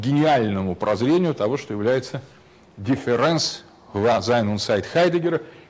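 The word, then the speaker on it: Differenz: то пишется так, difference, это немецкое написание латинского слова, но лучше произносить его так, а не difference, как у Дериды,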